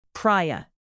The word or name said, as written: praia